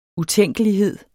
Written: utænkelighed

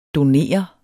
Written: donere

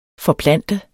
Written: FORPLANT

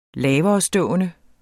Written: laverestående

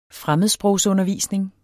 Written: fremmedsprogsundervisning

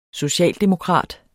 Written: SOCIALDEMOKRAT

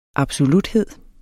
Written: absoluthed

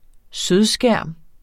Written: sødskærm

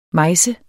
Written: mejse

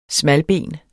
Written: SMALBEN

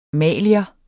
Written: malier

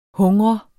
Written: hungre

